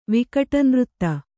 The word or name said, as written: ವಿಕಟನೃತ್ತ